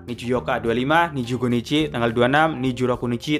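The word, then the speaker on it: Nijuyoka (二十四日): Niju harusnya kan Niju Yong, tapi bacanya Niju Yok, Pak. Tanggal 24 Niju Yok, Pak, nggak ada Nichinya.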